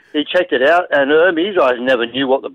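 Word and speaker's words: Hermès: Hermes. – It's Hermes.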